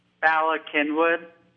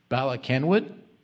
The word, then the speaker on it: Bala Cynwyd: You're going to have to pronounce the name of the town that you're in. – Bala Cynwyd. – Bala Cynwyd? – Yep, that's the name.